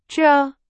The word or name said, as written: 这